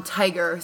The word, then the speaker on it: Tadhg: It's spelled T-A-D-H-G, but they call him Tiger.